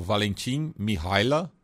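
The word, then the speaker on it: Valentin Mihăilă: Valentim Mihaila. É, é, foi assim que ele me ensinou a pronúncia do atacante da Parma, do, do Parma, que é, fez dois gols contra a Suíça de visitante no, nos acréscimos e empatou.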